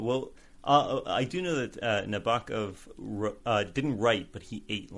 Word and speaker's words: Nabokov: There is a poem that he wrote to explain how to pronounce his name. The gawk of a loon at night prompts Nabokov to write. So you got to rhyme it with the gawk of. So Nabokov.